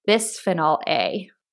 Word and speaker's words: bisphenol A: And then there's also polycarbonate with bisphenol A, which I think you're actually I think that's right on this.